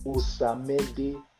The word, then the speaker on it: Osamede: Osamede.